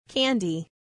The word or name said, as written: candy